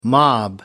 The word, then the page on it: mob: American English